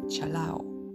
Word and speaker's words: chalado: En español se pronuncia chalao.